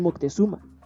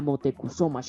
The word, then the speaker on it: Motecuzoma: Gracias a sus relatos, Cortés supo de los mexicas, de su gran ciudad y del gran Moctezuma, como lo pronunciaban los extranjeros, pero cuyo nombre era Motecuzoma Xocoyotzin,